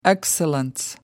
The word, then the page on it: excellence: American English